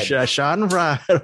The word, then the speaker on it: Schadenfreude: How do you pronounce it? Schadenfreude. Very good use of that word, Eric Holmes.